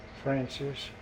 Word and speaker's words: Francis: E-R-D-T. – Okay. – Zip code. – 10081. And what's your full name? – Francis. – Do you spell it Francis with an I? F-R-A-N-C-I-S? – Yes.